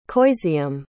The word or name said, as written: coiseam